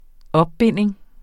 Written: OPBINDING

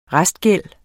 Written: restgæld

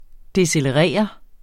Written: decelerere

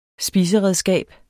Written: spiseredskab

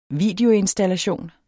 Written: videoinstallation

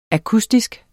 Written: akustisk

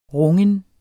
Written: rungen

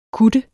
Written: KUTTERNES

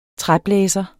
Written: træblæser

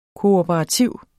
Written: kooperativ

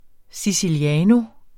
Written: siciliano

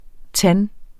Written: TANDEN

